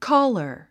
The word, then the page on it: caller: kɔ́ːlə r; コーラァ